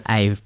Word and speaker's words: Ave: Ave, like avocado.